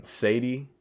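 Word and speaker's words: Cédé: It's Sadie, but it's pronounced C... Or, I mean, it's spelled C-E with a, like, accent over it, D-E.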